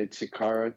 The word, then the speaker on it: Chikara: Am I pronouncing your name correctly? I, I, I hate it when people mispronounce names, so I just got to check that with you. Is it Chikara? – Yeah, Chikara Tanaka.